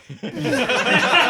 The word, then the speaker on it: somatic: Is it semantic? Did I say it right? – Mm-hmm. – Yeah. – Or is it somatic? – Somatic. – Somatic. – Somatic. Semantic is not it.